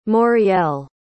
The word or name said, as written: moriel